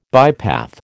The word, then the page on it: bypath: American English